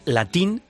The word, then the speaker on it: Latín: Bueno, el subtítulo es, Latin Lovers, con acento en la I, que juega, con tilde en la I, que juega con lo de Latin Lover, ¿no?